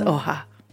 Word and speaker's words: Oha: In lieu of calling me something that sounds like a mud boot falling down a flight of stairs, if you aren't sure how to pronounce my name, just ask me, baby. It's Oha.